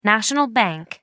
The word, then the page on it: national bank: American English